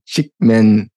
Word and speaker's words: Csikszentmihalyi: So if you're familiar with that book, Flow by Mihai Csikszentmihalyi, I believe it's pronounced.